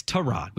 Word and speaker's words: Tyrod: By the way, it's Tyrod. We keep saying Tyrod.